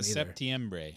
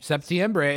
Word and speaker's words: Septiembre: Cinco de Septembro, because I don't know how to say it in Spanish anymore. – Septiembre. – Septiembre is correct.